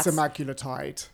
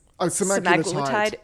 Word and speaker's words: semaglutide: Like semaglutide.